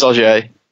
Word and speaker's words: Ogier: I got shouted at last week for pronouncing Ogier differently. – I've seen that. – Podcast. – Yeah. – It is Ogier, for the record.